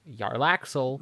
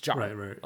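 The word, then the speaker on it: Jarlaxle: There's been quite a few NPCs I've looked into where I thought it was, you know, Yarlaxel, right. – But then it's Jarlaxel. – Right. Yeah. – D&D seems to like the hard J's.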